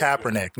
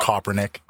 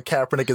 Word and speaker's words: Kaepernick: Kaepernick, not Kaepernick. – I mean, whatever his name is. The guy with the afro. – Can you say his name right? It's Colin Kaepernick.